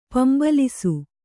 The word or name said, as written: ಪಿಂಬಾಲಿಸು